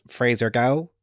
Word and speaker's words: Fraser Gow: And today I have Fraser Gow.